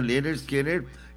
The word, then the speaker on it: Lynyrd Skynyrd: Lynyrd Skynyrd. Ajá, así se pronuncia Lynyrd Skynyrd.